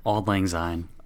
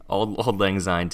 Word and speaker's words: Auld Lang Syne: And in this moment, we get to see the entire town packed into the Bailey's living room, celebrating, drinking, cheersing, and ultimately singing Odd Lansing. How do you pronounce that? – Auld Lang Syne. – Auld Lang Syne together.